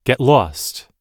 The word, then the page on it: get lost: American English